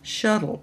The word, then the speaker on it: Schoettle: I also would like to refer you to a wonderful publishing company, Shuttle Publishing Company, and that Shuttle is spelled S-C-H-O-E-T-T-L-E.